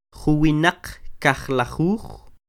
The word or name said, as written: Juwinaq kajlajuj